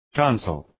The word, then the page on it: tonsil: ‘tɒnsəl